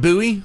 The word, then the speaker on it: Bowie: We're going to start off with David Bowie. I know the English people like to pronounce it Bowie, but they're wrong.